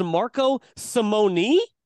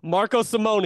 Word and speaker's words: Simone: It's actually pronounced Marco Simone, which I think is very weird. I've been pronouncing it Simone for a long time now, and now I'm being told via this pronunciation email for the Ryder Cup, Marco Simone Golf Club, which sounds off.